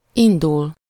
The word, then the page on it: start: American English; [stɑɹt]; British English; /ˈstɑːt